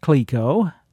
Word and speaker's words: Clicquot: Clico is spelled C-L-I-C-Q-U-O-T and pronounced Clico, and was the fictional name of the Eskimo boy that was pictured on the bottle and in the company's advertising.